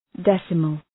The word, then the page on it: decimal: ‘desəməl